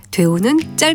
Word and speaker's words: 되우: 그런데 되게와 된통은 모두 첫 번째 음절을 길게 발음하는 반면에 되우는 짧게 발음한다는 점에 차이가 있습니다.